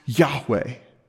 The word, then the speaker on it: Yahweh: When you read it in the original language, in the Hebrew, that name is sometimes pronounced Yahweh.